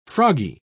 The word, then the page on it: froggy: British English